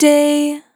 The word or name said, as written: Short Day